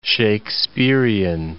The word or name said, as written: Shakespearean